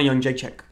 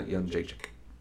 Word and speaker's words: Jędrzejczyk: Joanna Jędrzejczyk. – Joanna Jędrzejczyk.